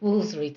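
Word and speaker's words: Woolfardisworthy: I love The Tea Room, The Buddha's Eye, and The Two Aging Gay Socialites, and Baroness Astamoffet of Wolfardis Worthy, actually that's pronounced Wolseley, darling, and Miss Dorothy Gwendolyn Hunt and Blather are such wonderful characters.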